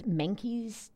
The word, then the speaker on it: Menkes: It's Menkes disorder, which I hope that I'm saying that correctly, yeah. – M-E-N-K-E-S, this rare disorder.